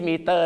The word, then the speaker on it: meter: คำว่า make ต้องออกเสียงเหมือนกับรถเขียวเหลืองที่ผ่านมาเราบอกเขาจะไปไหนแล้วเขาไม่รับนั่นแหละครับ taxi meter นั่นเ